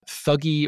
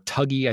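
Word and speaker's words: Thuggee: and they come across this thuggy or tuggy, I think they pronounce it multiple ways in the movie, this Indian murder cult out there in the Indian countryside.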